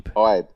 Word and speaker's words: Ibe: Yeah, one syllable it's Ibe.